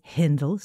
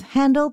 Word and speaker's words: Handel: Some people say Handel, some say Hindel, some say Handel. I personally go with Handel because he was German, but he moved to England and spent like the whole rest of his life there, and that's how they pronounced it there.